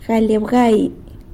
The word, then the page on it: galy abgai: sanlucasquiavini